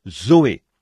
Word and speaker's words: zoé: Et en grec, pour dire la vie de Dieu ou la vie éternelle, on se sert du même mot que l'on prononce, Zoé, Z-O-E.